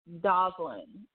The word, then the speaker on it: Dvalin: Dovlin. It's D-V-A-L-I-N. So I am going to pronounce it Dvalin, maybe I'm pronouncing it.